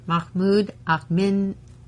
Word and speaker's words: Ahmadinejad: Mahmoud Ahmadinejad. – Ahmadinejad.